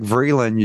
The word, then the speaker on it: Vreeland: People can find me, online, Derek Vreeland.